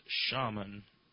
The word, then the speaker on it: shaman: I think it's funny, says Trigger, how Jick says shaman. I pronounce it shaman. Except he writes out SH-AH-M-AH-N, shaman.